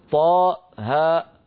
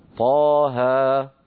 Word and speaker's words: طه: يعني لا نقول حاء ميم بل نقول حاميم، لا نقول طاء هاء بل نقول طاها.